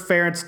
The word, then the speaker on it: Ferentz: Ferentz defense.